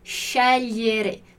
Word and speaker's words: scegliere: Então, scegliere. Então, o che ali, né, o SCHE, E com som de CH, e depois o GLI com som de LH.